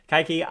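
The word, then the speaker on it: Kaiky: It's K-A-I-K-Y. It's Kaiki. I'm not sure what it is.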